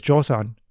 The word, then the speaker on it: Joseon: The modern-day Korean pronunciation is Joseon, and thus we have the first name for Korea ever.